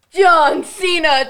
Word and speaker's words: John Cena: John Cena.